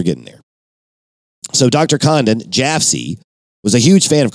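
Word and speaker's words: Jafsie: The 72-year-old Dr. Condon would soon come to be known to the world by the pronunciation of his initials, JFC, as Jaffsy.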